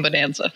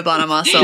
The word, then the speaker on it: Bonamassa: I mean, I know there's a proper way of saying it, but I always call him Bonanza. – I used to like Joe Bonamassa a lot when I was little. – That's how you say it.